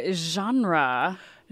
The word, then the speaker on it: genre: So there it is, that je sound at the beginning. But in English, the sound je typically occurs in the middle of all these French borrowings. Genre, also borrowed from French. And many English speakers are changing the way we pronounce that word to make it sound more English. In English, we're very comfortable with the sound j at the beginning, judge, jury. And so we're getting genre.